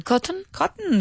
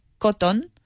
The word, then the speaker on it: cotton: We wouldn't pronounce the O just as much. We wouldn't say cotton. It's cotton. – Cotton. – Yeah. You hear it almost stops there. Cotton. – Mm-hmm. Mm-hmm. – That's how we pronounce it.